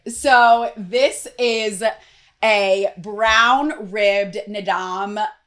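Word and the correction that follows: Naadam: I believe I'm pronouncing that right. I actually wanna look it up. You see, I try. Nadam. So I didn't pronounce it right.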